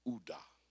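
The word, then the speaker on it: Uda (יהודה): In the Hebrew tongue, it's Uda. Uda.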